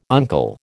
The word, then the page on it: uncle: American English